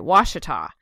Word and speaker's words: Ouachita: So, which is how I even said it right, Ouachita.